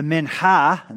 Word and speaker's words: mincha: The mincha, and that's how you say it. They're very phlegmy in their voice.